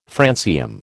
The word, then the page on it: francium: American English